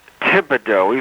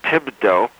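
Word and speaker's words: Thibodaux: And the town in Louisiana is pronounced Thibodeau. Even though it's a THI, it's pronounced Thibodeau.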